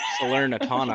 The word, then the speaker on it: Salernitana: Salernitana, I believe is how it's pronounced.